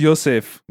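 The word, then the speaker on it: Josef: Yeah, Joseph Mengele, yeah. I think it's actually pronounced Yosef or something, but yeah.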